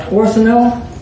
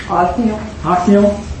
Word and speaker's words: Othniel: You know, Orthanel, he's a judge. Go to Judges 3:11. – Othniel.